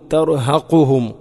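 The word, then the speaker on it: ترهقهم: അബ്സ്വാറുഹും തർഹഖുഹും തർഹഖുഹും ഖാഫ്, തർഹഖുഹും അല്ല. ഓതുമ്പോൾ പലപ്പോഴും കാഫ് കൊണ്ടാണ് നമ്മൾ ഓതാറുള്ളത്.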